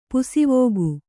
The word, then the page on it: ಪಾಸುವೊಕ್ಕು: kannada; pāsuvokku